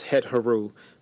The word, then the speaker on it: Hathor: which was originally pronounced het-heru by the ancient Egyptians.